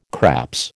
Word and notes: craps: American English

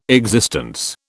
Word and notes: existence: British English

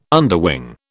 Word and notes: underwing: British English

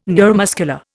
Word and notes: neuromuscular: Indian

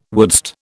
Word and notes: wouldst: British English

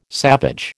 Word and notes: savage: American English

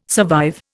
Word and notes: survive: Indian

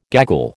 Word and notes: gaggle: American English

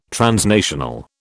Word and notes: transnational: British English

